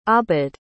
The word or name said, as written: abd